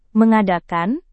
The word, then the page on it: mengadakan: Indonesian